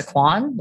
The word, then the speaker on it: Kwan: Melissa Kwan, last name is spelled K-W-A-N.